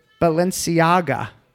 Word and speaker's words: Balenciaga: And then, maybe if you want to go, we could totally put this into the, I'm going to try it, guys, Balenciaga. I said it right? I did.